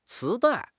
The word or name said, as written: 磁带